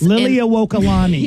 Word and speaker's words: Liliuokalani: You can also go, you can park your boat or you can drive over to Liliuokalani. Which is Liliuokalani.